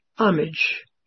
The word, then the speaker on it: homage: This is one of those words that has an H in front of it that's not pronounced. Homage.